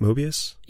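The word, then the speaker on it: Moebius: Mobius? – Yeah.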